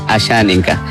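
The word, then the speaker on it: Asháninka: A ver, Ameyetari Ashaninka. – La pronunciación es Ameyetari Ashaninka.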